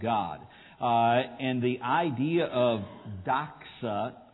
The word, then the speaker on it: doxa: It actually is the Greek word is the way we would pronounce it is doxa.